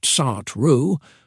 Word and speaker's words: Sartre: Sartre, pronounced Sartre, brings a bold and liberating message: you are free, and with that freedom comes responsibility.